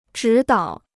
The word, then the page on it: 指导: zhǐ dǎo